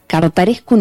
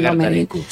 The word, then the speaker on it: Cărtărescu: y esta joyita maravillosa que es Cartarescu en Uruguay. Lo dije bien que estás con el rumano vos. – Mircha Cartarescu. Sí, lo dijiste perfecto.